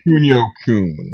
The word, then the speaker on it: Kunio-kun: You know, are the Kunio-kunu games... I can't even say it. How do you say it? – I believe it's Kunio-kun.